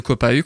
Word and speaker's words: Copahu: Et U de Copahu, comment tu écris Copahu Comme ça se prononce C-O-P-A-H-U. – Effectivement, comme ça se prononce. – Comme ça se prononce. Sauf que le H.